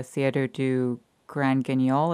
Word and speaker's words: Théâtre du Grand Guignol: I'd love to talk more about art and creative endeavors, maybe through talking about your article about the Théâtre du Grand Guignol.